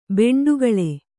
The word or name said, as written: ಬಂಡೆಕಲ್ಲು